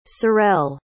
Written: Sorrell

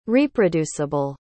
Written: reproduceable

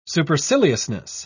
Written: superciliousness

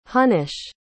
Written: honnesh

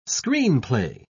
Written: screenplay

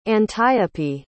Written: antiope

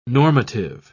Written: normative

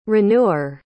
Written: renewer

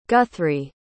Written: guthrie

